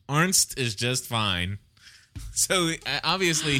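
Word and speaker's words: Arzt: Stephanie, you want to do Hurley and I'll do Arndt. – Okay. – All right. Go ahead. – Let me ask you something, Arndt. – It's Arndt. – Arndt. – Arndt. No, not Arndt. Arndt. A-R-Z-T. A-R-Z-T. Arndt.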